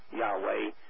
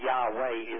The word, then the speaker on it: Yahweh: Now, you know the causative verb to be I Am Haya, Eliasher Haya, which is Yod-Heh-Vav-Heh in the Hebrew, Yahweh, using the vowels in the English. And that's approved by over 95% of the Hebrew scholars, that Yahweh is the way that you would pronounce it.